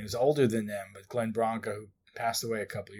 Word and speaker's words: Glenn Branca: So I had read that Kevin Shields was a big fan of Glenn Branca, is that how you say his name? – Glenn Branca, yes.